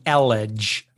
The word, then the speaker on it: Elledge: So what I've learned is to really kind of almost hyphenate the Elledge. You yes. know, um, pronounce both syllables very clearly. Right right? Pull that L.